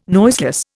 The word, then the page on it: noiseless: Indian